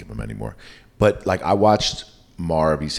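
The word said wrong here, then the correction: Mare: How do you say Mar of Easttown? – Mayor of Easttown. – Mayor. – Mayor.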